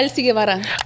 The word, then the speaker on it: Elsy Guevara: Yeah, Elsy Guevara.